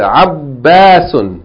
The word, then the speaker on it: عباس: This word, عباس, has a shadda on the ba and a long vowel. And you have to pronounce the shadda. عباس.